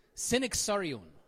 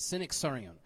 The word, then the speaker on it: synaxarion: So the, the, the, the pronunciation of this is synaxarion. Synaxarion. So this is a Greek term, and this is, how, this is I guess, the pronunciation of it, synaxarion.